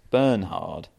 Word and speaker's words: Bernhard: Pronounced exactly like it's spelt, Bernhard. The H is not silent, so not like Bernhard Tomic. – Like Bernhard Langer.